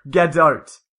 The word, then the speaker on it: Gadot: It's pronounced Gaul, G-O-L-L, or is that Gaul? Gaul? – Oh, this isn't helping at all. – Gadot, G-O-D-O-T-E. – Oh. – Because the A's are pronounced as in short O's. So it's like Gaul, Gadot.